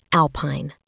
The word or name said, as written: alpine